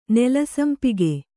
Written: ನೆಲಸಂಪಿಗೆ

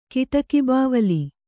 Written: ಕಿಡಕಿಬಾವಲಿ

